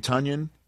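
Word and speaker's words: Tonyan: don't call me Tanyan Tunyon, rhymes with Funyan Tunyon.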